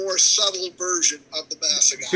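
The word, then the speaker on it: Más Agave: Asagave, did I say it right? – Oh, Masagave.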